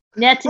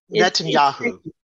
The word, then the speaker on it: Netanyahu: Is it Netanyahu? – Netanyahu. – Right. Netanyahu. Like Yahoo. Okay.